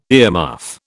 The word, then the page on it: earmuff: British English